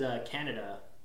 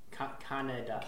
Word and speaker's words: Kaneda: His name was Kaneda. – Kaneda. – Kaneda, but it's spelled with a K. Tetsuo. And every time he says it, I think he's saying, like, the country.